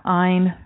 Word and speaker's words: Ayn: Yeah, Ayn rhymes with mine, Ayn Rand Institute.